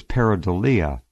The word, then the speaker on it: pareidolia: Now I see the proper way to pronounce the word is pareidolia.